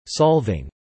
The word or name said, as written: solving